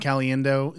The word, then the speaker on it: Caliendo: It can be Al Pacino walking around and then like Frank Caliendo, is that how you say his name? – Yeah.